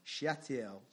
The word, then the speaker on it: Shealtiel: Does anybody know how to pronounce that? Shealtiel. Shealtiel, yeah.